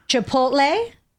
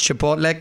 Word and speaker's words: Chipotle: Chipotle. That's the fast food chain. – People actually mispronounce Chipotle. Come on. – There are people that do that people call it Chipotle.